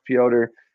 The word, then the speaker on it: Piotr: It's spelled Peter, but it's pronounced Piotr.